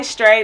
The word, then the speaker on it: Stray: It's spelled STRAY Stray.